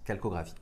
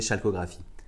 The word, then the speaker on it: chalcographie: Alors calcographie, ça s'écrit CHALCO et graphie, mais ça se prononce calcographie. Je le dis parce que moi au début je disais chalcographie.